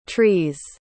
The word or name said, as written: trees